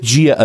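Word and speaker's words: JEA: And when you say Gia, I think with a G, but you mean with a J.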